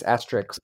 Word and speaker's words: asterisk: The Astros. – There's another good point. – No, asterisk. You pronounced that wrong. It's asterisk.